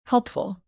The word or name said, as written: helpful